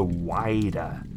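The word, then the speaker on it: Whydah: Yeah, this is a ship called the Wider, which is spelled weirdly. It's not like wider, like, so like gaping or anything. It's W-H-Y-D-A-H, right? – Wow.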